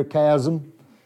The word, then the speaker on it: chasm: Is that how you say it down here, chasm? And we, okay, chasm, chasm. Good. I think that's maybe closer to right.